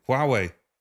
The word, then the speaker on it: Huawei: So now we see with, I never know how to pronounce it, Huawei, is it Huawei? – Huawei.